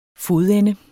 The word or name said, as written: fodende